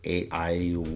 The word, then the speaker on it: aiuole: It's spelled, it has every single vowel, so it's aiuole, A-I-U-O-L-E. So the only consonant is an L, and that's why I love that word, because it happens to have every single vowel in it.